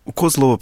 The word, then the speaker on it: Козлово: Так почему у нас мы говорим село Козлово, а не Козлово? – Козлово, потому что в карельском варианте всегда ударение на первый слог падает,